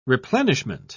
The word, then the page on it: replenishment: British English